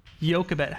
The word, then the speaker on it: Jochebed: Jochebed.